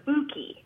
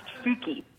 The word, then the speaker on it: Fouquet: Finally, this guy is allowed to use his real last name, which is Fouquet. That's how it's pronounced, Fouquet. – Fouquet. Fouquet. Not spelled that way, but pronounced that way.